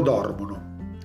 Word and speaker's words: dormono: Noi dormiamo, voi dormite, loro dormono. Acá también alla persona ellos, loro, l'accento cae nella terzultima sillaba e no en la penúltima o anteultima, come sea.